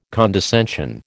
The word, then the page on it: condescension: American English